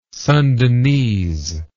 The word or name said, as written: Sundanese